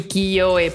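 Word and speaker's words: Kiyoe (聖恵): Sí, Kiyoe, porque, o sea, la Y no es per se para en en como una consonante, es como una vocal también.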